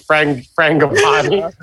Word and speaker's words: Frangipane: Did I say it wrong? It's Frangipane. – Frangipane? – That's how they say it in Great British Bake Off.